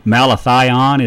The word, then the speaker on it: Malathion: Malathion is a good choice. – How you spell that? – M-A-L-A. T-H-I-O-N.